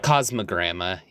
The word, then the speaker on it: Cosmogramma: Am I saying that right, or is it gamma? It's gramma, right? – Cosmogramma.